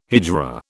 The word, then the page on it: hijra: British English